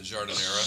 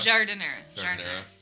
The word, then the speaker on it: giardiniera: It literally said, spicy giardiniera. Is that how you say it? – Yeah. The giardiniera. – Giardiniera.